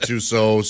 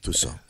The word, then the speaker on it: Tussaud: The person who worked there pronounced it Tussaud, so I'm going with that. – Yeah, Tussaud.